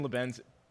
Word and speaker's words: Lebanz: But really excited to have Nathan Lebanz. Am I pronouncing that? – Lebanz. Correctly. Excuse me. Lebanz, I say. Yep. – Apologies. Nathan Lebanz, really excited to have you here.